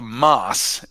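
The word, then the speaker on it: Maß: So that is called a Moss, and it's spelled M-A.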